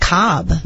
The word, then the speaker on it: Kotb: You know, how do you pronounce her last name? When they call someone constantly, it's K-O-T-B. – Kotb. – Maybe it's just Cobb. – No, there was a pronouncer from, because when I did the story earlier, there was some guidance and it was Kotb.